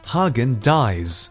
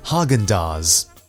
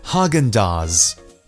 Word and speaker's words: Häagen-Dazs: Many people in Hong Kong pronounce this as Hagen Dies, but the correct pronunciation is Häagen-Dazs. I am surprised how many people say Hagen Dies, but remember to say Häagen-Dazs.